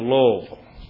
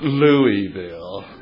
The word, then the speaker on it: Louisville: You come to Louisville. And I know y'all seen the Kentucky Derby on TV. It's not pronounced Louisville. And it's not called Louisville. It's pronounced Louisville. I'm telling you, don't go to that town and pull in there and ask somebody, hey, listen, is this Louisville?